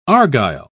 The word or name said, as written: argyle